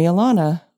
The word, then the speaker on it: Alana: Now, you might call me Alana. Most people do. Growing up in California, that's pretty much how it's pronounced here.